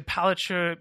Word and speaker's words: Palaszczuk: You know, Dan Andrews, Richard Marles, Anastasia Palaszczuk, is that how you say it? – Yep.